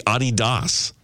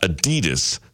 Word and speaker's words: Adidas: And apparently we're pronouncing Adidas wrong. It is supposed to be Adidas. – Oh, no, I hate that. – With a slight emphasis on the first syllable, not Adidas, stressing the second.